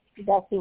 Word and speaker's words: Bessie: Bessie Watkins, B-E-S-S-I-E. Last name Watkins, W-A-T-K-I-N-S.